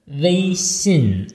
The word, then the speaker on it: Weixin: Also äh, Wei Xin, einmal flach, einmal unten.